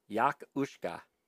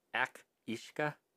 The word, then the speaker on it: Each-uisge: So it's spelled Each-uisge. – Mm-hmm. – The Scottish pronunciation is Yak-uisge. I'm probably going to get all these fucking wrong. – Yak-uisge. – Irish is... Ak-ishka, mm-hmm. – And in the Isle of Man, it's Kabil Ashti.